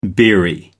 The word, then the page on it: beery: American English